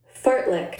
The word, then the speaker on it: fartlek: Fartlick. Fartlick. Well, like, F A R T L E K. – But it's pronounced fartlick.